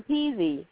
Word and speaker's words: Peze: I'm Peezy. Yeah, and it's pronounced just the same way it's spelled, P-E-Z-E.